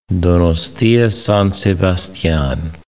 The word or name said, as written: Donostia-San Sebastian